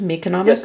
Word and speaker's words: Meconomics: Is that how you pronounce it? Meconomics? – Yes, it is.